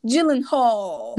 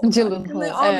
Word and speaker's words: Gyllenhaal: Bu şarkının da ilk orijinal versiyonu 10 dakikalıkmış zaten ve zaten en bomba kısmında Jake Gyllenhaal. Gyllenhaal.